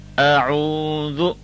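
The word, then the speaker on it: a'udhu (أعوذ): Now thal is a letter that is pronounced by the tip of the tongue. A little effort will get this beautifully pronounced. Now if you listen carefully, a'udhu billahi minash shaytanir rajim. Now we do not say a'u zo, zo with a z sound, but we say a'u the thal sound.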